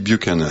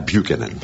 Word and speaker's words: Buchanan: Alors on dit Pat Buchanan à la française, ou Buchanan. – Lui, il dit Buchanan. – Alors, si on prononce américain, c'est Pat Buchanan.